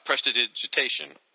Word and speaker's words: prestidigitation: To describe the art, I mean, what you've got in the foreground is he's doing, you know, prestidigitation. I almost said that, right? – I think and you did say it right. Very good.